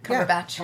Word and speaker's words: Cumberbatch: Cumberbatch.